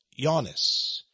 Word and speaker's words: Oannes: By the way, on page 723 of this Masonic textbook, they discuss the great fish god, Yannis. That's spelled, by the way, O-A-N-N-E-S, Yannis, or John.